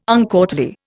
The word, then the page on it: uncourtly: Indian